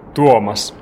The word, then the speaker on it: Tuomas: Can I just get you, first of all, just to tell me how to say your name properly? – Tuomas. – Tuomas. – Yeah, pretty good.